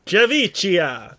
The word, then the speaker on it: Javicia: They've casted a new Batwoman in, I'm going to say it's Javicia. I think it's J-A-V-I-C-I-A. I think this C is a soft C. Javicia Smith. – Javicia.